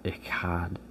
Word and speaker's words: echad: But the word one in this verse is echad. It's E-C-H-A-D. It's echad. Like, and the C-H is, I can't make that noise, so it's not pronounced like that, but it's E-C-H-A-D.